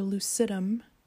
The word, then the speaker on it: lucidum: And I want to pause for a moment and just talk about that second word, lucidium, or lucidum, actually, is probably how it's pronounced.